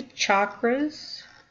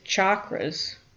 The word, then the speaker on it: chakra: The word chakra in Sanskrit means energy wheel. In Sanskrit, the CH sound is a hard sound, as we would say church or chili.